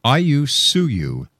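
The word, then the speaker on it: Ayllu Suyu: And that's pronounced Ayusuyu.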